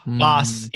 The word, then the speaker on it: Boss: Nope. Boss. Yeah, it's Boss. It's Boss, like double S.